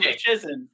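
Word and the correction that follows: Luszczyszyn: for the longest time, but it's loose chicken.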